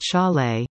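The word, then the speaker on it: Šalé: The 18-year-old Sale, pronounced Shaw-Lay, was taken 20th overall by Seattle at the draft on June 28 in Nashville.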